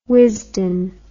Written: Wisden

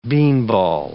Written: beanball

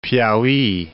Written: Piauhy

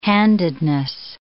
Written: handedness

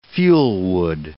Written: fuelwood

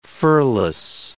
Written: furless

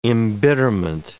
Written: embitterment

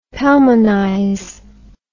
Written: pelmanize

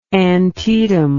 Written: antietam

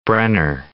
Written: Brenner

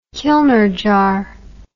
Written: kilner jar

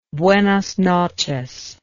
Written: buenas noches

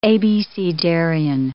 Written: abecedarian

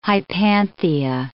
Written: hypanthia

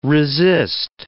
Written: resist

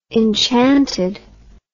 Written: enchantedly

